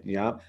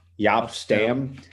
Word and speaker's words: Jaap: How do you say it? Yop. Yep. – Yop. Yop. – Jaap Stam.